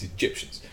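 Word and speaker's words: Gyptians: There's this group, I'm going to call them a group at this point, called the Egyptians, not Egyptians. This okay. – It's spelled literally everything but the E. It's Egyptians.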